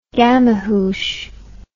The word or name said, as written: gamahuche